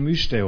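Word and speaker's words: müjde: Aber drunter steht myjde, also das J wird im Türkischen wie J ausgesprochen, da steht Myste oben.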